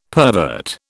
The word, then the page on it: pervert: British English